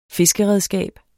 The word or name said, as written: fiskeredskab